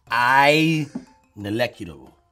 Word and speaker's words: ineluctable: The word is ineluctable. – Ineculable. Ineculable. – Say it again. – Ineculable. – No. – Ineculable. – You're jumping a syllable. – Ineluctable. – You added another syllable.